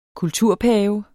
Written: kulturpave

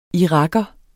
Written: iraker

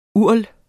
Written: URL

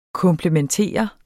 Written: komplementere

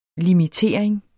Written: limitering

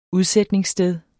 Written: udsætningssted

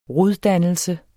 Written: roddannelse

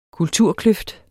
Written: kulturkløft

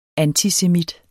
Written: antisemit

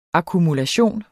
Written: akkumulation

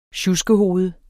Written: sjuskehoved

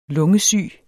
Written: lungesyg

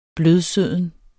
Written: blødsøden

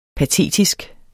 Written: patetisk

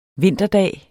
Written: vinterdag

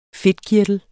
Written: fedtkirtel

